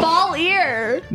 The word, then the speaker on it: Belyor: It's two syllables. – Yo, home, smell you later. I'm off to Bel-Yor. – Valir. – No, it's Valir.